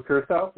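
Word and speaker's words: Curaçao: and we had an excess amount of blue Carioca. Is it pronounced? I can't remember. – Blue Curacao, yeah. – Yeah, blue Curacao.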